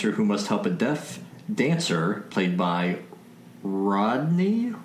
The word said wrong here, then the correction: Rondi: It's Rondi Berrialt, I believe. – Rondi Berrialt. That's a good one. A much better pronunciation than I made.